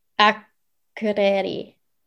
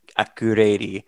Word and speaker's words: Akureyri: So forgive me if I don't do pronunciation justice, but it's Akureyri. Yeah. – On a map, like that's one of the, like you probably lucked out by going to Akureyri.